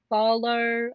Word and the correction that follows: Afualo: I have no clue. – Oh, yeah. I don't know how to pronounce her last name. – Drew Afualo.